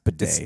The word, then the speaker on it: bidet: Maybe when he used one of their bathrooms that don't have a debate, the they just... – It's pronounced bidet.